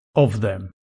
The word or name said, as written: of them